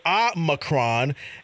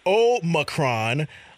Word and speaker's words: Omicron: But apparently, there are many pronunciations that people are giving to Omicron, the 15th letter of the Greek alphabet. I don't know what that means. So some people may pronounce it Omicron and Omicron. The Oxford Dictionary includes Omicron.